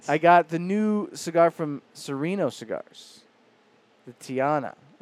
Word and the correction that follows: Taino: Is that how you say it? Taino. – Taino. – I don't even know. – Like a Taino Indian kind of thing.